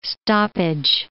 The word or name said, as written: stoppage